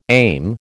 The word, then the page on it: aim: American English